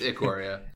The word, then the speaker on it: Ikoria: So well, forget about whatever you heard. It's Ikoria. – Ikoria. Okay, cool. I thought I was saying it wrong when I was working on it, and apparently I was saying it right. – The, cool. the way I remember it is Icky.